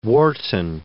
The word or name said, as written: Wharton